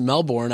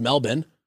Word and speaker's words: Melbourne: I always pronounced it Melbourne. I was wrong. It's Melbourne.